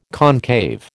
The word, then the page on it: concave: American English